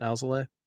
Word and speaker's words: Alzolay: Some bullpen guys, Edbert Alzolay, I think is how you pronounce it. Alzolay.